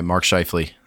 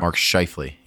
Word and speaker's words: Mark Scheifele: All right, Mark Scheifele. Turned it up. Something fierce in the last 10 games. – Hey, you said it right. Good job. – Mark Scheifele, yeah.